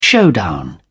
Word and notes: showdown: British English

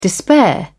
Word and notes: despair: British English

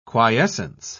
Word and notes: quiescence: British English

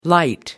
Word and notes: light up: British English

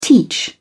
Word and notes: teach: British English

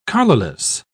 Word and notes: collarless: British English

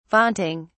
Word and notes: vaunting: British English

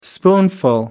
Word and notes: spoonful: American English

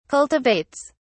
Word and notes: cultivates: British English

